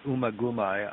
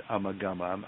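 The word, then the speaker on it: Ummagumma: John pronounced it Umaguma. I always said Amagama. I'm not sure what the actual pronunciation is, but it goes all the way back to '69.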